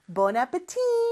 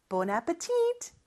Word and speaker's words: Bon appetit: Bon appetit 혹은 미국에서는 마지막 T까지 발음해서 Bon appetit 이렇게 말하는 경우가 있다고 했어요.